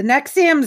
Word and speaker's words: NXIVM: The NXIVM scandal.